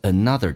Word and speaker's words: another: Buy me another，首先我们看一个单词 another，这个单词不要读成 another，那个 an 在单词当中是发两遍的，即便是有重音符号分隔也要发成 another 不是 another，同时 me 跟 another 可以有一个连音，元音跟元音之间的连音，不是加我就是加也，那这个加个也叫 buy me another， buy me another dress。